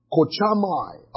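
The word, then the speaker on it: kochamai (καυχάομαι): It's the word kochamai. I'll spell it for you. K-A-U-C-H-A-O-M-A-I. K-A-U-C-H-A-O-M-A-I.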